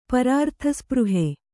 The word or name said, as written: ಪರಾರ್ಥಸ್ಪೃಹೆ